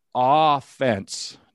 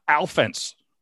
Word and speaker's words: offense: For the record, it's pronounced offense, not offense.